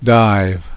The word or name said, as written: dive